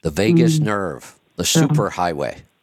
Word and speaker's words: vagus: Is it vagus? Vagus nerve? Okay, I said it right then.